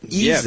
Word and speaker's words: Isaias: Now they pronounce it Isaias.